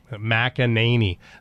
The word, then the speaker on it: McEnany: Someone said it's Alex, it's McEnany. – Yeah. – McNamee. You say McNamee. – Yeah, sometimes I just say it quick. – Yep. McEnany. Must be a Scottish person. Wants that name pronounced properly. McEnany. Get it right.